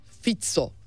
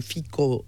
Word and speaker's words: Fico: Ee, özellikle tabii Slovakya'da Robert Fitso. Fitso okunuyormuş. Tekrar düzeltiyorum. Fi, Fico yazılıyor. Fico diye ben size aktardım ama Fitso okunuyormuş.